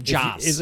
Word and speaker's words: Joss: And how do you pronounce his first name? I hate the spelling of his name. J-O-S-S. – Joss.